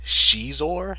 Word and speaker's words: Xizor: That's Prince Xizor. Is that how you pronounce it? – Yeah, that's how you pronounce it, correct.